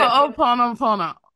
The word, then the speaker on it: Ho'oponopono: Ho'oponopono. I can never get it. – Ho'oponopono. – There we go. – Ho'oponopono.